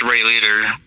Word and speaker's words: Ray Leader: You know, Facebook doesn't let me have my outlaw thing, so my Facebook is Ray Leader, my actual name. And spell that for the kids. – It's R-A-Y and then L-E-A-D-E-R.